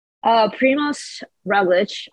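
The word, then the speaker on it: Primož Roglič: So how do you pronounce Primoz Roglic and Tadej Pogacar? Like, what's the correct way to say their names? – Primoz Roglic, obviously, and then Tadej Pogacar.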